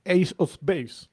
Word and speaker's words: Ace of Base: Ace of Base.